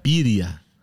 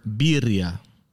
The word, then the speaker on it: birria: Am I saying that right? Oh, yeah, birria. – And put it in anything. – For those of you, that's a Spanish word, birria.